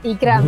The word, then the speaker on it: Ikram: Eso es, Ikram. – Muy bien. Oh, ya tenía yo ahí mis temores de que al final se me colara la E, porque los españoles esto de las E's, sobre todo las iniciales que van...